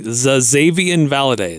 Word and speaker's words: Xazavian Valladay: Now, this is a team that um, Xavier Valade. I have to make sure I pronounce it correctly. Xazavian Valladay. It's got an X, a Z, and a V in his first name.